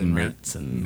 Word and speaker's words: Märzen: It's spelled Marzen, so it looks like Marzen, but I believe with the umlaut over the A, it's pronounced Märzen.